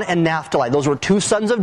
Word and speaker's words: Naphtali: Naphtali and Zebulun, that's the way they're pronounced in the Old Testament.